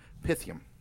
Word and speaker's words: Pythium: Pythium.